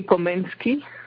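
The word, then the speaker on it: Komenský: Well, in Czech, I think they say Komensky.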